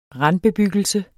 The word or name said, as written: randbebyggelse